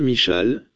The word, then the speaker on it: Michal: Comment prononcez-vous son nom? Je ne sais pas comment ça se prononce en anglais donc je dirais juste Michal, comme l'hébreu, Michal.